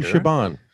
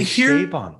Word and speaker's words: Chabon: I pronounce it Chabon. Am I wrong there? – It's actually Chabon. – Here's the thing. – Is it Chabon? I mean, I'll stand corrected.